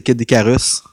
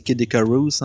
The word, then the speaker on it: Kid Icarus: Ben, oui, vas-y, Moi, mon jeu que j'ai choisi, c'est Kid Icarus. – Ouais. – Oui. – Ça le prononce mal, je sais que c'est Kid Icarus en anglais.